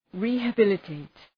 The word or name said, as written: rehabilitate